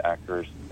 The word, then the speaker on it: Acer: Harriet Acer, David Acker's, I'm sorry, Harriet... His last name is spelled A-C-E-R, and it's actually pronounced Acker.